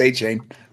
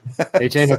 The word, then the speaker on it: Achane: Like, and Devin Achane, I think he said it's Achan. There's now another way to pronounce his name, I heard. Is for real too. – We're pack committed. It's Achane. – Achane, okay.